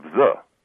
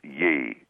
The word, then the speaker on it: ye: And so I'm here to tell you that it should be, if you see ye old rub shoppy or whatever, it should be pronounced the, not ye.